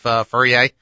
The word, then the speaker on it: Fourier: Tell me, is it the French pronunciation of Fourier? – Yeah, it's Fourier. It was actually named after the French mathematician Fourier.